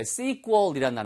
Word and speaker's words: sequel: sequel이란 단어. S-E-Q-U-E-L. sequel. 우리가 sequel 하잖아요. 그게 sequel이 정확한 발음인데 그 단어를 쓰셔도 되고요.